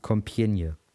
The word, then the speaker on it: Compiègne: Compiègne.